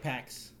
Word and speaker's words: Pak: My last name is spelled P-A-K, which many people just say Pack.